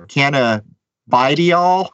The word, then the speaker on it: cannabidiol: And I don't know if I'm saying this right. Cannabidiol or cannabidiol, something like that. – Cannabidiol. – There you go.